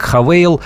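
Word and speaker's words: Haval: Одна из моделей э, марки Хавейл, китайской марки, пишется как Хавал. Э, наше представительство рекомендует произносить как Хавейл.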